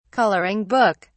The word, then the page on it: coloring book: British English